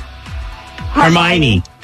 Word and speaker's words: Hermione: Did I say that right? Is it Harmony? Because I don't... – Hermione.